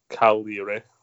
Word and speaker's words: Cagliari: Cagliari.